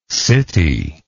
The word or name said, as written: citylike